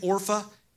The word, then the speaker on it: Orpah: They married Moabite women, one named Orpah and the other named Ruth. Orpah might sound familiar. Sounds like Oprah Winfrey.